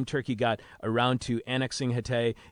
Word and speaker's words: Hatay: That area is called Hatay Province.